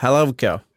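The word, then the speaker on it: Helewka: Jag, jag youtubade honom här, så jag hörde hur de transatlantiska, eller amerikanska, eller kanadensiska eh, eh, journalisterna... Eller, ja, så, du har till och med uttalet där, eller? – Ja, Helevka.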